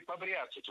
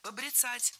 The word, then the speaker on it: побряцать: Побряцать неверно. Правильно, побрицать. Это слово имеет настолько непривычное ударение, что большинство людей не слышали и не представляют, что правильно побрицать, брицать. Ударение в этом глаголе и в однокоренных глаголах ставится за корнем.